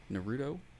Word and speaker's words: Naruto: Uh, and you might know her from some work she's done on Fallout New Vegas, Guild Wars 2, Bleach, Naruto. Did I say that right? Naruto? – Yep.